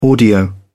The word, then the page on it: audio: British English